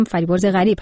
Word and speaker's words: فریبرز غریب: با پوزش از تلفظ اشتباه فامیل همکارم که غریب نیا گفتم، فریبرز غریب هستش، همکار عزیز و کارشناس ورزشیمون.